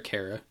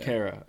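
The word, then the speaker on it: Khaira: And if so, would you think about adding Kyra, I don't know how to pronounce his name, from Edmonton? – What is K-H-A-I-R-A. I know the player. – Jujar Kara. Yeah. – Kara. All right.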